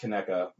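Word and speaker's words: Conecuh: It's properly pronounced what? – Properly pronounced Kinetta.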